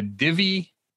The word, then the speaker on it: Divvy: And Jenny was riding, and tell me if I'm pronouncing this right, is it a Divvy bike? – Yes, D-I-V-V-Y? – Okay.